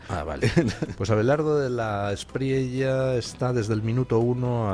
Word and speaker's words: De la Espriella: Abelardo de la Espriella está... Por cierto, ¿cómo se pronuncia don Guillermo? ¿De la Espriella? – De la Espriella.